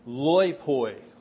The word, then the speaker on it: loipoi: And the rest of the men, the word rest is a Greek word that I'll pronounce loipoi.